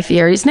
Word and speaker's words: Fieri: It's the correct pronunciation of Guy Fieri's name, which is Fieri.